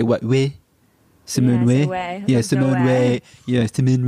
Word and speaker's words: Weil: She's also French, but you know what's interesting is that I pronounce her name Weil because she's an Ashkenazi Jew, and there's no W in that language, you know.